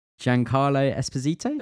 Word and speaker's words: Giancarlo Esposito: Giancarlo Esposito? – Yeah, that sounds good.